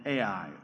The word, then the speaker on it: Ai: From the Negev, he went from the place to place until he came to Bethel, to the place between Bethel and Ai," or I, some pronounce it.